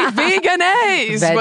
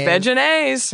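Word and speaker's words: Vegenaise: It rhymes with mayonnaise. It should be veganase. But it's veganase.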